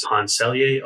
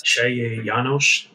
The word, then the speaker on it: Selye János: A Hungarian-Canadian scientist who in English is Hans Selye, all my Hungarian friends say it's Selye János is the correct way to say it.